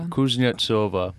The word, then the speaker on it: Kuznetsova: Kuznetsova.